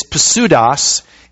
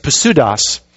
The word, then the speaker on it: pseudos: It's pseudos. We don't pronounce the P in English, but in Greek, every single letter is always pronounced, which makes it easy to read, actually. But it's pseudos.